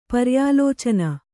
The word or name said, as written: ಪರ್ಯಾಲೋಚನೆ